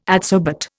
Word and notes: adsorbate: Indian

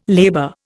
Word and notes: labor: Indian

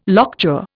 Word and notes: lockjaw: Indian

